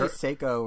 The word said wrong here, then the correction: Sekiro: or how do you pronounce it? – I've heard great things about Sekiro. I've heard great things about. – Did I just say Seiko?